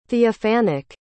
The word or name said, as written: theophanic